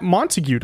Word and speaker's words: Montague: And then we also have Matt Montague.